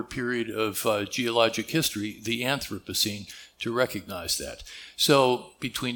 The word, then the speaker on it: Anthropocene: If there's any doubt about the pronunciation of this word for many of you, I'm told from the geophysicists that Anthropocene is the correct word to use, or the correct pronunciation that I can't pronounce today.